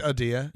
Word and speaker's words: Adia: Maybe it is Adia. – Yeah. – Wow. – Adia, or maybe it's like Adia. – It's Adia.